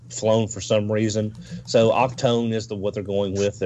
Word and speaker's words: Octone: The next picture is going to be Octone. Not Octane, Octone. And please tell me that's a misspell. – No, that is from Hasbro. That is the way it is being pronounced.